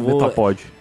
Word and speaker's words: Metapod: Metapod. – É, eu acho que a pronúncia é essa, né? – É, essa mesmo.